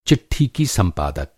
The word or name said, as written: संपादक